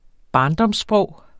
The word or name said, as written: barndomssprog